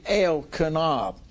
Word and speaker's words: Elkanah: When I say Elkanah, anytime you see El in a word, it's representing God. It's pronounced like Elkanah. El Shaddai. Got it? But we in America, a lot of times we would say El Shaddai, but you need to separate because it's recognizing the name of God, the E-L, Samuel.